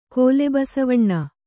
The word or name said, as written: ಕೋಲೆಬಸವಣ್ಣ